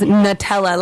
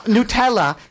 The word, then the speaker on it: Nutella: Well, my name my name is Natalia, but my nickname is Nutella, like the hazelnut spread uh, Nutella chains.